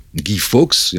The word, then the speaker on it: Guy Fawkes: C'était donc des, des catholiques, euh, menés par un certain Guy Fawkes, Guy Fawkes, et on prononce ça à la, à la française,